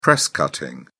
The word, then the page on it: press-cutting: British English